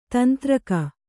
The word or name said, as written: ತಂತ್ರಿಕೆ